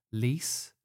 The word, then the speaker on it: Lys: And they are, and you can correct my pronunciation, Lys.